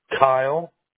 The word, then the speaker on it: Kyle: Kyle. – K-Y-L-E? – Yep.